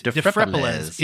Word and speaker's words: Dphrepaulezz: So his first life, he was born Xavier Amin de Frepeles. – Good job on that. I just found his name. – Right? It is de Frepeles. It is a incredibly weird D-P-H-R-E-P-A-U-L-E-Z-Z.